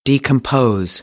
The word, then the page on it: decompose: American English; oʊ